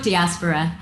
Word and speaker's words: diaspora: All of the panelists and myself are part of the Indian diaspora, or diaspora. We were kind of trying to figure out the pronunciation. I'm gonna go with diaspora.